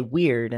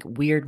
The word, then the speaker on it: Wyrd: And I keep thinking of, you know, you say weird, and I know it's spelled W-Y-R-D, but I think of weird. A modern word, maybe. W-E-I-R-D.